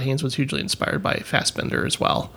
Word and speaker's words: Fassbinder: I've seen a couple of his films, but it's been a while with Fassbinder. That's how you pronounce it. But Fassbinder.